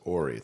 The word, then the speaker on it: Horry: So Horry would have taken you to. – It's Ori. It's a silent H.